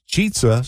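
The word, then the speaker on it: Chizza: Yeah, it's actually a mashup of chicken and pizza, and it's pronounced cheetza, so it rhymes with pizza.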